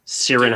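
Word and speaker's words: Ciarán: Hines, H-I-N-D-S. – Hines. – Yeah, and then first name is C-I-A-R-A-N. Sirian Hines.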